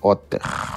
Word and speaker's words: Otter: Como é que se escreve? O-T-E-R. – É Otter. – É. Vamos baixar aqui, vocês de casa ou que estão assistindo aí, baixem também, vamos lá, testar. – O-T-T-E-R. – O-T-T-E-R.